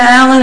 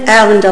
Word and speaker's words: Allen: Antonina Allen, it's spelled A-N-T-O-N-I-N-A, Allen, L-L-E-N, it's 51 Foster Lane, Palm Coast, Florida.